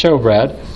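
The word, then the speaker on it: shewbread: Now, if you're a reader of the King James Bible, maybe you have seen the word showbread spelled with an E, S-H-E-W-B-R-E-A-D, but it's pronounced showbread.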